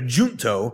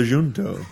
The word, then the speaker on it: junto: I was calling it a junto because I thought it was a hard J. When I got there, they're like, welcome to the junto.